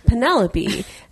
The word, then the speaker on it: Penelope: She was like, Penelope. And she's like, that's weird. And she goes, because it's spelled like envelope. And she goes, yeah, and you don't say envelope-y.